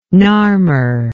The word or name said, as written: narmer